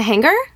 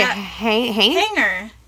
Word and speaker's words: hanger: You pronounce the G too. – Do you say hanger? – Hanger. No, there's no G in it. – There is a G. It's a hanger. – It is not. No, but you don't pronounce it.